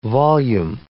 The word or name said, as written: volume